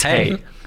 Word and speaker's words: Hej: It's hey, as we say in Swedish. – H-E-J. – Yeah, here we go. – One of my favourite things when you go to Sweden is people going, hey, hey.